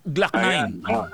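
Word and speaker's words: Gloc-9: Yung ano bang pag-pronounce yung Glock 9? Glock 9. – Glock 9.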